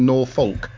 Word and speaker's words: Norfolk: That's how it was phonetically pronounced, isn't it? Norfolk. – Norfolk, yes.